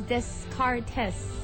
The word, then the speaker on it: Descartes: But that's wrong because his name is Descartes. – Yes. – Yeah. – Well, it's French, so silent S.